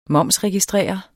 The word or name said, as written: momsregistrere